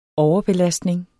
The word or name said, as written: overbelastning